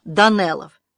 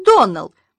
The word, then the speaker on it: Доннелл: «Мне очень жаль», — пробормотала Аня, тщетно пытаясь припомнить какое-либо происшествие, связанное с детьми Доннеллов. «Клариса Эльмира сказала мне, что вы произносите нашу фамилию Доннелл. Так вот, мисс Ширли, правильное произношение нашей фамилии Доннелл, ударение на последнем слоге.